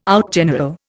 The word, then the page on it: outgeneral: Indian